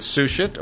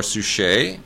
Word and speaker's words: Suchet: PBS did a wonderful job with The Mystery of Agatha Christie with David Suchet, or Suchet, depending how you want to pronounce it, which is all about the life of Agatha Christie, which we know nothing about.